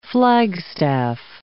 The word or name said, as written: flagstaff